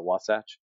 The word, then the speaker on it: Wasatch: First of all, did I say that right? Is it Wasatch? – Yes. Well done.